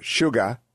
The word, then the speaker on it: Suga: I think it's pronounced Mr. Suga, S-U-G-A, to replace Shinzo Abe.